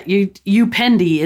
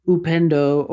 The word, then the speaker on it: upendo: Yeah, upendi is the pronunciation that upsets him so bad because it's not upendi, it's upendo, or where he grew up, it's mapendo.